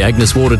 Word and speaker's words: Agnes Water: Heading down to Agnes Waters. – Right. It's pronounced Agnes Water.